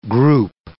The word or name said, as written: group